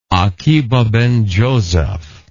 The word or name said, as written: Akiba ben Joseph